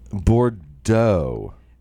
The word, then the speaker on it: Bordeaux: Bordeaux, right? There's an X at the end. We don't say it.